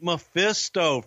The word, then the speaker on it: Mephisto: The great Memphisito. – The great Mephisto, Frankie Lane, man. He was a legend in your, he's from your part of the woods in Alabama. – Yeah, I just mispronounced the fuck out of it. My bad. – That's okay. It's great Mephisto.